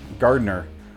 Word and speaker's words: Gardner: Gardner, I say. The daughter says Gardner. Adds an extra syllable.